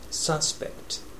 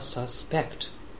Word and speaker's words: suspect: Rioting 暴動、 Looting 略奪、 Suspect 名詞の場合は容疑者という意味でアクセントが最初にで Suspect 名前動語です。動詞の場合は Suspect と呼んで疑うという意味です。